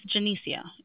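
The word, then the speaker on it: Jenecia: And it's Janicia.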